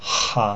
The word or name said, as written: ha